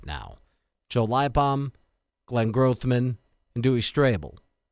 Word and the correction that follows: Strobel: I guess it's Strobel. Everyone else pronounces it Strobel.